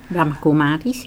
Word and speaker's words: Brahma Kumaris: Je bent, misschien moet je wat meer vertellen over de Brahma Kumaris. Heb ik het goed uitgesproken? – Brahma Kumaris, dat is een internationale organisatie op spiritueel gebied.